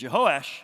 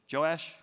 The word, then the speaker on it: Jehoash: And Jehoash, I told you, they say his name both ways. Joash at the beginning and Jehoash was 7 years old when he became king.